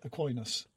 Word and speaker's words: Aquinas: how would you say it? Aquinas.